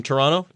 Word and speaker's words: Toronto: when you're driving sort of along the Lakeshore Drive there in Toronto, notice how I said it properly Toronto, not Toronto, Toronto.